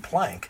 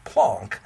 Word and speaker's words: Planck: And so many people pronounce his name Planck. Mm-hmm. In fact, I've heard chemistry teachers say, oh, Max Planck this and Max Planck that. But it's Planck if you pronounce it the right way.